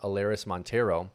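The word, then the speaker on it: Elehuris Montero: I'm going to butcher the name, but Alaris Montero. – You got it right.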